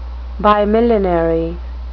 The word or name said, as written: bimillenary